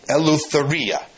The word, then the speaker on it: eleutheria: And it's here on the board right now, eleutheria. Okay, that's the Greek word for freedom in Galatians chapter 5, verse 1. Eleutheria, it's a five-syllable Greek word, and it means freedom.